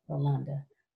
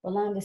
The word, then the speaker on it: Rolande: We were talking about, Your name is pronounced Rolanda? – Uh-huh. – Okay, Rolanda. Rolanda, see, I told you these are my little senior moments.